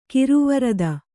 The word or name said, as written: ಕಿರುವರದ